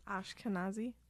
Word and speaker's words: Ashkenazi: Ashkenazi.